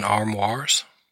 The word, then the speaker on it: armoire: Now you can store things in armoires. Did I say that right? – Armoire, yes.